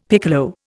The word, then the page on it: piccolo: Indian